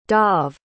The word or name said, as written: dov